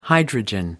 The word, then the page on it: hydrogen: American English